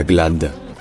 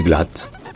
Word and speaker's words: Glad: Et en fait en allemand, le D à la fin d'une particule se prononce T. Donc ce n'est pas Glad, c'est Glatt.